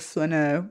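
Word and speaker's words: flâneur: a flaneur.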